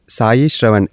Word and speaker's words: Sai Sravan: Telling that this is Sai Shravan, S-A-I, S-R-A-V-A-N. He told, I want 9 syllables. Don't put a H in between, he said.